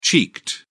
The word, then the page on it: cheeked: British English